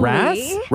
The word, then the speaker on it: Rass: R-A-S-S. – Rass. – Yeah. – Okay. Rass. And it's not like ass, right? Like it's not get your ass out of here, right? – Actually.